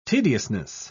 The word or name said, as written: tediousness